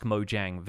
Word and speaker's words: Mojang: It's kind of used informally, and they've explained this in one of their previous Ask Mojang videos, and I've been saying it with the soft J, the kind of Swedish J that's sort of more like a Y sound from English, for a while, but apparently the company name is Mojang.